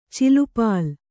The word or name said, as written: ಚಲ್ಲಪಿಲ್ಲಿ